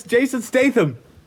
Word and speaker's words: Statham: It's Jason Statham. Did I get it? – Yeah.